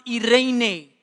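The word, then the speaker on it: eirene: The word peace there in the Greek is pronounced irene.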